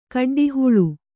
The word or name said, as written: ಗಡ್ಡೆಹುಲ್ಲು